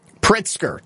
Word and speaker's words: Pritzker: Pritzker, Tom Pritzker.